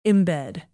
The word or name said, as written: embed